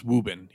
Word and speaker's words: Woburn: But yeah, no, so it's pronounced Woburn, even though it's spelled Woburn.